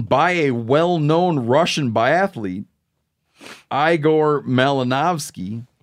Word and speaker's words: Igor: Igor.